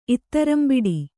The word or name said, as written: ಇತ್ತರಂಬಿಡಿ